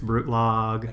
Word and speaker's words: Brutlag: It's Brutlag.